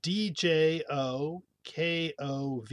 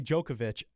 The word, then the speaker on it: Djokovic: But I hear Novak Djokovic typically. – Yep. The one thing Serbians tend to have, I think that the V-I-C sound is more like Vich, I've heard. – Djokovic, okay.